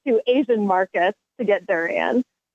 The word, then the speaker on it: durian: So we talked about before the show started, we talked about that picture of that new fruit, durian. Did I say that right? – Yes, you did.